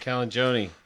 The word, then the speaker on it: Calagione: Calagione. – Thank you, Sam. It's almost Calagione. Close to Chuck Mangione.